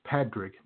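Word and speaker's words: Pádraig: Exactly, and because in Ireland, it's Padraig, not Patrick. And so the D. – Thank you. – That's the Irish pronunciation. – It's like P-A-D-R-A-I-G-H.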